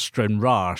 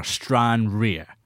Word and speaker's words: Stranraer: Well, look, I'm going to give Lee a bit more stick back here because he just pronounced Stranraer, Stranraer. That is out of order, Lee.